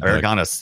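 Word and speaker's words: Aragonés: Sergio Aragones, how you say it? Or Aragorns? – Aragones is what I always said. – Aragones. There you go.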